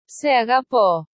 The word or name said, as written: se agapo